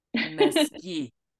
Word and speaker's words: Mesqui: And it would be right to pronounce it Mesqui. – Mesqui.